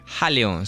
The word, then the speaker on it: Halon: Me dice Ileón, Ailión, ¿cómo lo pronuncia usted? – Jaleón, se escribe H-A-L-O-N. – Jaleón, bueno, yo lo estaba diciendo muy castellanizado, pero aprendo todos los días y estoy feliz de reconocer los errores.